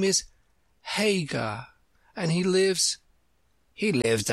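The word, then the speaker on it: Haigha: His name is Hagar. – He pronounced it so as to rhyme with Maya.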